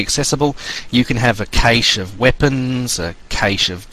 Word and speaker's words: cache: That is spelt C-A-C-H-E, but it's pronounced cache. Yeah, it sounds like cache.